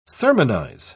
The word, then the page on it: sermonise: British English